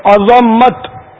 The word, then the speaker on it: عظمت: دو سوال کیا آپ نے، عظمت اور عظمت دونوں میں فرق ہے. عظمت اگر زوئی کو ساکن پڑتے ہیں نا تو اس کے معنی ہڈی ہوتے ہیں، اور عظمت جو کہ درست تلفظ ہے، عظمت معنی وہ عظیم ہونا بہت بزرگ ہونا بڑا ہونا، تو اصل تلفظ ہی عظمت ہے. ہم لوگ اردو میں عظمت بولتے ہیں تو یہ بھی چل جائے گا، لیکن عظمت کے معنی ہڈی ہے، تو صحیح تلفظ عظمت ہی ہے.